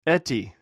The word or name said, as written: eti